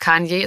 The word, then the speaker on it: Kanye: Und es ist Kanye, ist auch absolut richtig.